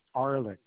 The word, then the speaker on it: Arlyck: Yes, it's Ralph is first name and Arlick is the last name.